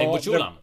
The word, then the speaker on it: Egbuchulam: Eh, det var alltså, med reservation för uttal här, Chisom Egbuchalam. Egb... Eller? Egbuchulam. – Ja, Egbuchulam.